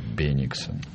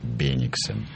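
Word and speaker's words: Беннигсен: Давайте договоримся сразу об ударении. Леонтий Бениксон. – Конечно. – Бениксон. Вот давайте, хотя я здесь очень часто представляю вот французскую сторону этого исторического конфликта, вот иногда, но будет он у нас Бениксон.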